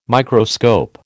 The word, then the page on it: Microscope: American English